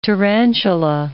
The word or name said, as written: tarantula